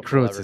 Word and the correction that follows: Kurtz: It's Kurtz.